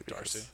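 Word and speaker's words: D'Arcy: DRC? Is that how you should pronounce that? – I'm going to say Darcy. – Darcy.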